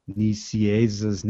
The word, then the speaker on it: Nicieza: And he also says his pronunciation of Fabian Nicieza's name is Nicieza. Ah, as in way. Oh, so Nicieza. – Nicieza. – As in pizza. So Nicieza.